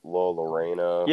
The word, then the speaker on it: La Llorona: Yeah, yeah, you told me you were going to watch that, like, La Llorona.